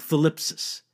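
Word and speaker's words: thlipsis (θλῖψις): In fact, the ancient Greek word for suffering, you pronounce it something like thlipsis, originally had the idea of actual physical pressure.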